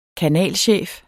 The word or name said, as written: kanalchef